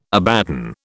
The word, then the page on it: Abaddon: British English